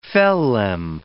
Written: phellem

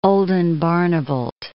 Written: Oldenbarnevelt

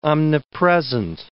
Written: omnipresent

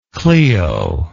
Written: Cleo

